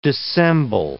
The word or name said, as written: dissemble